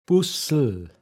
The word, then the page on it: åogschmacheg: Pinzgauer Mundart